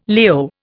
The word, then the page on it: Leo: Indian